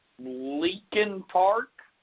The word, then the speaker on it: Leakin Park: So February 9th, Hae's body is actually discovered in Leakin Park.